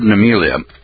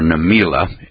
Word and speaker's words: Niemela: But Richard Nemelia, or Nemela, is spelled N-I-E-M-E-L-A, if you want a copy of his material.